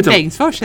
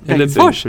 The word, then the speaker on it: Bengtsfors: Men Bengtsfors är det. Bengtsfors. Eller Bengtsfors. Med betoning på fors.